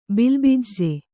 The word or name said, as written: ಬಿಲ್ಬಿಜ್ಜೆ